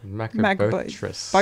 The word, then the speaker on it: macrobotrys: Whilst at Geelong Botanical Gardens yesterday, we were lucky to see their Strongylodon macrobitris. Wow, I butchered that. – Strongylodon. – Strongylodon. – Macrobotrys.